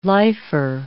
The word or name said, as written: lifer